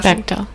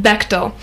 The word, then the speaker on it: Bechdel: Have you read, I finally read recently, Alison, how do you pronounce her last Bechdel. – Name? Bechdel.